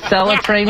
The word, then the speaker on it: Acela: Is it Acela train, Melissa? – Yes.